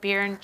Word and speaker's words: Barron: But I know it's also at Barron Cheese. Am I saying that right? Yep, it's okay.